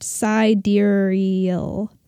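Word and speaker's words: Sideræl: S-I-D-E-R, and the A and the E are together, L. Oh, Sidereal is pronounced Sidereal.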